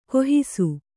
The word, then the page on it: ಗಾಹಿಸು: gāhisu